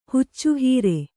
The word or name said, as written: ಹುಚ್ಚುಹೀರೆ